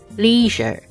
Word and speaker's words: leisure: Leisure。 Leisure is spelled L-E-I-S-U-R-E S-U-R-E，你要发 Leisure。